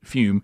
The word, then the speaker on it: Füm: Now Füm is spelled F U M, but you pronounce it fume.